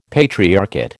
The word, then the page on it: patriarchate: American English